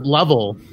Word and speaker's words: Louisville: And so, I heard some people pronounce it Lovell.